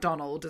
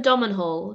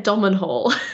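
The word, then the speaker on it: Domhnall: So it's got Donald Gleeson in. I'm saying that right, not Donald, as I repeatedly like to call him, it's not Donald. – I think I call him Domenhall.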